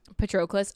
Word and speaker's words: Patroclus: Patroclus. – Patroclus.